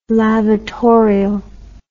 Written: lavatorial

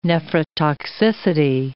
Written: nephrotoxicity

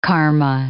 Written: karma

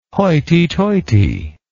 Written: hoity toity

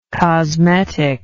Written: cosmetically

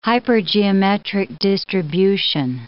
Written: hypergeometric distribution

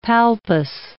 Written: palpus

